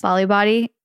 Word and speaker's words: Bali Body: But anyways, I will tell you, I used Bali Body. Is that how you say it? – Yes. – Bali Body.